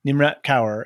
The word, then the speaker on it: Nimrat Kaur: So the actor here who's playing Yana is Nimrat I don't know if I'm going to pronounce this. – Kaur. – Nimrat Kaur.